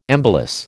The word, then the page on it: embolus: American English